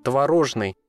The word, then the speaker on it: творожный: Кстати, в родственном прилагательном ударение будет одно – «творожный». Тут уже без вариантов.